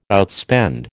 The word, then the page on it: outspend: American English